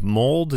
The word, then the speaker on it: Mould: Mould is that how you say it? – Yeah.